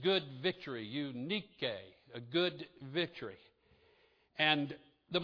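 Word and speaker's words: Eunike: So her name, the first part of it is you, which basically means good. And the second part is nike, unike. That was how she would say her name in Greek.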